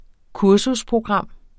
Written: kursusprogram

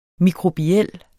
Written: mikrobiel

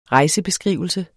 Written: REJSEN

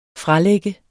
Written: FRALAGT

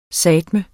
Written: sateme

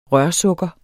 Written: rørsukker

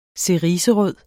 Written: ceriserød

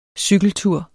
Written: cykeltur

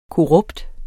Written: korrupt

